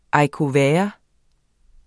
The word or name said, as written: haricots verts